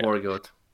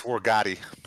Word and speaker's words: Wargotti: Wargoat. – It's Italian. It's Wargotti.